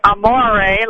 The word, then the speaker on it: Amore: I pronounce it Amore, like when the moon hits your eye, and we play the Amore song every day going in from our boat trips.